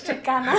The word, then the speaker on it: Chicana: It's Chicana. You said it wrong. My bad. – You just stop it. Chicana. Don't do that. – She's a female.